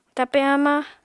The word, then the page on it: tape hamañ: Apatani